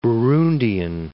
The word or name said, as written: Burundian